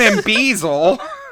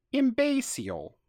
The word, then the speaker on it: imbecile: Yeah, for those of you who aren't staring at this script, the word is spelled I M B A S E A L. So I'm basile, which I believe is to be pronounced imbecile.